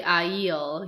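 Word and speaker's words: Ailill: Well, his name is actually Aiel.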